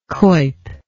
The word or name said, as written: Cuyp